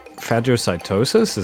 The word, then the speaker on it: phagocytosis: I shall use phagocytosis? Is that how that's pronounced? – Yes, it's eating cell.